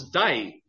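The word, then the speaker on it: day (δεῖ): That word must, in the Greek, it's three letters. We would pronounce it as day, just like day and night, day.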